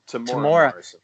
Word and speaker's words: Temuera: I have to go with favorite dark side character, Temeray Morrison. God, I hope I didn't butcher his first name. – Temora. – Thank you.